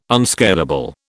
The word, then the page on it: unscalable: British English